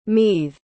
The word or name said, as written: meath